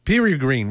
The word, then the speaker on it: Peregrine: Perry Green, mm. Oui, c'est des E, il faut prononcer en anglais des I.